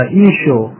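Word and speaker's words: ايشو (ܝܫܘܥ): والاخ وسام بين ان اسم المسيح عليه السلام بلغته الاصليه ينطق هكذا ايشو.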